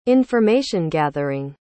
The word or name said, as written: information-gathering